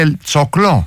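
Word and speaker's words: El Choclo: Αυτό είναι πώς προφέρεται Ελτσόκλο.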